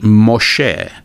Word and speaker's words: Moshe: And so Moses is really Moshe, and Jonah is really Yonah, and Noah is really Noach.